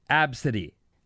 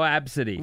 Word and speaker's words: ABCDE: Tracy had her 5-year-old daughter with her, and her daughter's name is ABCDE. It's pronounced Absidy. – Okay. So she's a knucklehead. – Well, she named her kid at A, B, C, D, E. – No, Absidy.